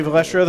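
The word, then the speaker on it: Valastro: Cake Boss Buddy Velastro. I think that's how you say his name. – Yep, Buddy Velastro, the Cake Boss himself.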